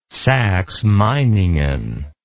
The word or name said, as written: saxemeiningen